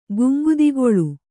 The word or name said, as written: ಗಂಗೆದೊಗಲ್